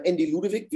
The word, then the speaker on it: Andy Ludewig: Ja, mein Name ist äh, Andy Ludewig, wie du bereits richtig gesagt hattest.